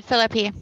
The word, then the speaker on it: Phillippe: Ryan Phillippe? Phillippe? Phillippe? – Yeah, that's it, Phillippe, yeah.